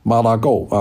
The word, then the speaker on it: Mar-a-Lago: Donald Trump 呢这段时间其实就没有离开他的家，没有离开他在那个佛罗里达的私人的那个海湖庄园啊，海湖庄园叫 Mar-a-Lago 啊，我们读成 Mar-a-Lago 啊，